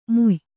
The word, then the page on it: ಮುಯ್: muy